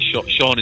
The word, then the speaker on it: Shaun: You just got to put my name in on any of those platforms, and it's my Sean is spelled S-H-A-U-N, uh-huh. and Atwood is spelled A-T-T-W-O-O-D.